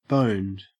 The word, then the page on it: boned: Australian English